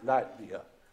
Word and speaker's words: Latvia: Levita. Hope I'm pronouncing that right. But Latvia, thank you very much. Latvia.